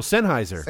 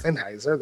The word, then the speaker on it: Sennheiser: It's this company I can never pronounce. It's Sennheiser. – Oh, Sennheiser. – Sennheiser, there you go.